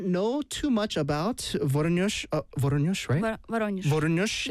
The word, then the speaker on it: Voronezh: your hometown name, I might say this wrong, but Voronezh. – Voronezh. – Yeah. – Voronezh.